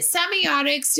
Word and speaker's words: semiotics: And is it semiotics? Do I say that right? – You did. Perfect.